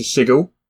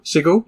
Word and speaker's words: Sigil: There's a 96-page guide to Sigil. Not Sigil. Sigil. It is correctly pronounced.